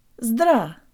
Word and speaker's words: здра: This is very close to part of the first syllable in our word. Let's put the Z on the front after we get a running start. Ta-da! Ta-da! Ta-da! Zdra!